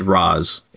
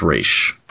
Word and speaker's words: Ra's: In fact, there was an episode of Batman Beyond where Terry McGinnis said Ra's, and Talia said it's actually pronounced Ra's.